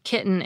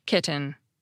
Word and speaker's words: kitten: The first time, I'll do it with an unaspirated T. Kitten. That probably sounded normal to some listeners and overly precise to others. Now, for my second pronunciation, this time I'll do it with a glottal stop. Kitten.